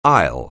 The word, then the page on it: aisle: English